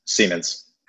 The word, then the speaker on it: Siemens: Siemens.